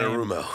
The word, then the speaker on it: Anarumo: What I like about AJ also is we talk all the time about that Lou Anonymous guy that I can't pronounce his last name. – Lou Anarumo. – Thank you.